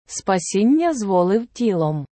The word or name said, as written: спасіння зволив тілом